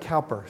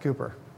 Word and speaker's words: Cowper: It looks like Cowper, C-O-W-P-E-R. but apparently it's pronounced Cooper.